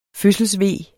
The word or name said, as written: fødselsve